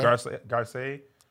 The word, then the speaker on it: Garcelle: Is it pronounced Garcelle? – Yeah. – She's Haitian. It might be French. – Yeah. – Garcelle. – We're going to say Garcelle for now.